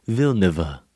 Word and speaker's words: Villeneuve: I'm hoping it's Villeneuve. – It's not. No, it's not. It's Denis Villeneuve. – That's where I probably got it. – It adds a third syllable.